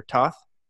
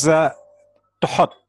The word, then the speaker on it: Thoth: Is it Thoth or Toth? What do you say? – Okay. It is Thoth, actually.